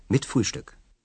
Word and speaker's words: mit Frühstück: 120 Mark, mit Frühstück.